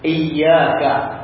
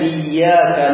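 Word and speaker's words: iyyaka (إياك): Ada yang membaca qira'us sab'ah, dan jumhurul qurra' mereka membacanya iyyaka na'bud, ya-nya ditasydid. Adapun Amr ibn Fa'id membacanya dengan kasrah ya-nya tanpa ada tasydid. Berarti iyyaka, iyyaka na'bud. Akan tetapi qiraah ini lemah.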